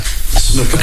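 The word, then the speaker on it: Snuka: Jimmy Superfly Suka. – Snooka. Okay. Pronounce it correctly.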